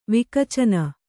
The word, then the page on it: ವಿಕಚನ: kannada